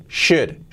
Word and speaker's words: should: Cuidado que no should es should, should, should. They should, should. La SH y la L no se, no pronuncia. Should.